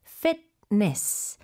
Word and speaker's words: fitness: 네, 영어도 똑같은데 발음 주의하셔야 돼요. 피트니스가 아니고 fitness. Fitness center.